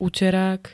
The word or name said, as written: uterák